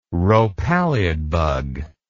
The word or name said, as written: rhopalid bug